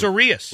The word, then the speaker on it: Urias: The baseball reference always has the pronunciations of these of all the players, really, that needs it. It's O-O-RE in all capital letters dash A-H-S in all lowercase. That's Urias.